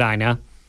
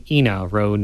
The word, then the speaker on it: Ena Road: Everyone, of course, calls it Ina, but it is actually Ena Road, named for Ena Giddings.